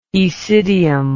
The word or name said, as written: aecidium